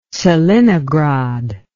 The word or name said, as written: tselinograd